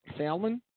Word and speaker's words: salmon: If you do it on that day, can I go a day after with salmon? Salmon? – Salmon. – The L is silent.